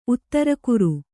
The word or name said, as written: ಉತ್ತರಕುರು